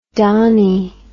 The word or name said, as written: Dani